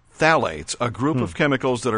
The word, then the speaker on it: phthalates: There's a thing called phthalates. T-H-A-L. Actually, it's not spelled that way, but phthalates. – Yeah. – It's with a silent P-H, so it's phthalates or phthalates.